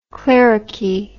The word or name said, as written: cleruchy